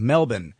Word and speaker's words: Melbourne: Melbourne, Australia, I guess it's Melbourne. – Melbourne. – Mm-hmm. That's the way you say it, more like Melbourne.